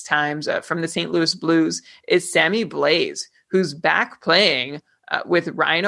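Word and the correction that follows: Blais: I'm laughing at these pronunciations. It's Blay and it's David Perron.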